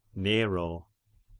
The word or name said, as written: Nero